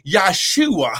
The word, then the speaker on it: Yahshua: And his name wasn't even Jesus because there's no J in the Hebrew language. It would have been Yahshua. How glory. But listen, can we still get saved? saved? Yes. But let's pronounce his name right. Yahshua HaMashiach. Yahshua the Messiah.